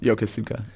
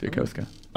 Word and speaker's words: Yokosuka: I'll be in Yokosuka at the Safari Zone event. – Yokosuka? – Yokosuka. Yeah, I found out the hard way that it is not pronounced Yokosuka. It's Yokosuka.